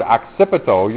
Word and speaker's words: occipito: And when you hear that word occipito, you're familiar with this one already, because what bone kind of rhymes with occipito?